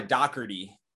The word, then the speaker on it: Dougherty: So I guess it's the D-O-U-G-H would come off super harsh. Like the G-H would almost be like a C-K, but I don't know. As I say, dialect and pronunciation for Ireland, I would love to get over there and hear somebody from where I'm from over there tell me how my last name is pronounced. But American, it's Jeremiah Dougherty.